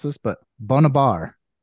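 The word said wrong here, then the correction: Bamidbar (במדבר): and it's, that is the meaning of Bamidbar. – Bamidbar. – So say it correctly for us.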